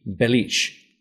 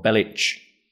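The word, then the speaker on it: Bellic: A proper pronunciation would be Belic or Belic.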